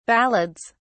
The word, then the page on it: ballads: British English